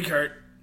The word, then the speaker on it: Kurt: You can call me Kurt.